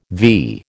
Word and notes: vee: American English

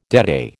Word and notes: deadeye: American English

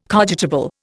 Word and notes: cogitable: Indian

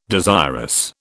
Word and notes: desirous: British English

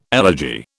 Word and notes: elegy: British English

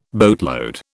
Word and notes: boatload: British English

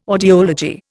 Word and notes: audiology: Indian

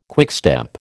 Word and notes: quickstep: American English

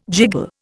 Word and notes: jiggle: Indian

